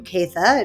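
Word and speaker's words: Caytha: And I'm on Instagram and Facebook as my name, Keitha.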